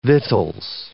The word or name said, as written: vittles